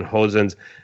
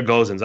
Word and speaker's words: Gosens: Okay, so Atalanta came out with Gallini in goal, Toloi, Palomino, Gimsiti as their back three, Hatebor, Deron, Freuler, and Hosens. Is it Hosens or Gosens? I've heard it pronounced both ways. – Hosens. – Italians pronounce them Gosens. – Okay. – Yeah. English-speaking world says Gosens, but...